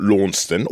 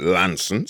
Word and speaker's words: Launceston: But my actual home is down on the Devon-Cornwall border, near a place called Launceston, or as they keep telling me down there, you've got to pronounce it Lanson. So for those who are Cornish, Lanson, that is where my home is, and that is where my farm is.